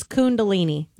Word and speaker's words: kundalini: But I was not familiar with kundalini. – Yeah. – Is it a kun or a kun? – Well, it's K-U-N. – K-U-N. Kundalini. – That doesn't answer my question. Is it? – It's kundalini.